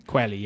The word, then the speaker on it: Qualley: Margaret Qualley? – Qualley, yeah.